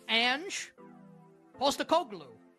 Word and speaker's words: Postecoglou: Ange Postecoglou.